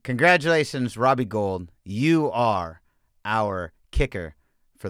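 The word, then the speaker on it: Gould: I mean it's it's not spelled like gold, but it's pronounced like like the like the the metal gold.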